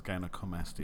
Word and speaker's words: gynecomastia: And I did mispronounce it. It is gynecomastia.